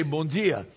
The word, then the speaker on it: bom dia: Okay, so if you want to be from the south, you say bom dia, because it's spelled that way, D-I-A. If you're from most of the rest of Brazil and you want to say it like I do, say bom dia. And the D becomes sort of a J, like a G or a J. Bom dia.